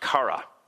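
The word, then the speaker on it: kara (χαρά): Well, very quickly, one of the things that we can see is that the word joy in Greek is pronounced kara, right?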